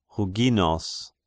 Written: Huginos

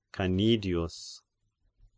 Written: Canidius

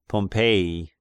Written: Pompeii

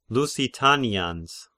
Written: Lusitanians

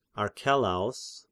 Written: Archelaos

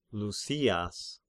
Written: Lysias

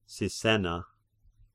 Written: Sisenna

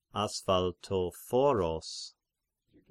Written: Asphaltophoros

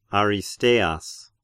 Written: Aristeas

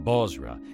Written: Bozrah

